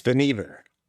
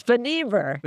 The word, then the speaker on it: Vannevar: It's Van Ever. – But everybody called him Van. I guess that made it a lot easier. I was putting the accent on the wrong syllable there.